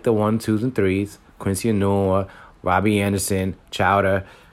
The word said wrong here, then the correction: Crowder: Well, I probably said his name wrong. I know his name is Jameson Crowder. My bad. There you go. Crowder.